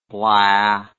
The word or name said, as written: blaa